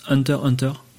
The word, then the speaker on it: Hunter x Hunter: dans un encart il dit que en fait ça se prononce Hunter Hunter, donc Hunter Hunter.